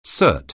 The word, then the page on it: cert: anglais